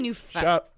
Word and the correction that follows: Newfoundland: Newfoundland. Yeah. Newfoundland. – Wherever, dude. – That's how they say it, though.